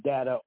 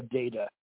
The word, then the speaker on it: data: All right, and as I said, someone who is maybe majoring in English might, although I think either pronunciation is correct, I think you could pronounce it data or data. You know, I tend to go with data with this more silent A.